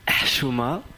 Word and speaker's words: Chouma: Ça se dit Chouma. Donc avec un H comme quand on a bu du coca.